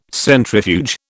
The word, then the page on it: centrifuge: British English